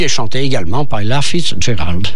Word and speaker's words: Fitzgerald: C'était donc Tease Get a Tasket, chanté par Ella Fitzgerald, pardon.